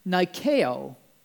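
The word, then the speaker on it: nikeo (νικάω): In fact, the Greek, original Greek word for overcomes, the verb, is pronounced nikeo. Nikeo.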